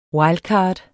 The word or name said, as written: WILDCARDS